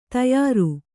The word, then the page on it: ತಯಾರು: tayāru